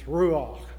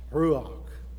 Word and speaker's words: ruach: Well, the Hebrew word in Genesis 1, verse 2, for spirit of the Spirit of God, is a word that's pronounced like this, ruach. You can almost hear breath coming out when you pronounce ruach.